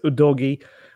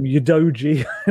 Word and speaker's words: Udogie: It's Udogi, and I've been calling him Udoji in recent days as well.